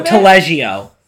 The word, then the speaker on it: Taleggio: Taleggio? – Ooh, that's a good one. Taleggio. – Taleggio. – Yeah, so that's definitely a flavorful cheese. I would say that Taleggio is a little bit more on the like the nutty variety.